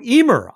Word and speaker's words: Ymir: And then 2000 came along with Emir, Y-M-I-R, in its wake. When sorting alphabetically, it takes the bottom cake. So, Emir, I hope I'm pronouncing it correctly.